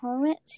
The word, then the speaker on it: Horowitz: I'm saying that wrong. Horowitz, yeah. – Horowitz, yeah, I think that's right.